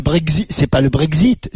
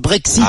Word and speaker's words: Brexit: Dis-moi, pourquoi tu remplaces le X par le Z, toi? – C'est-à-dire? – C'est pas le Brexit, c'est le Brexit.